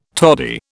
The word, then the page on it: tody: British English